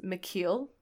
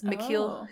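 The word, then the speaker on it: Michiel: And his first name is pronounced McKeel, even though it's spelled kind of like you'd pronounce it Michael. But it's McKeel Huseman.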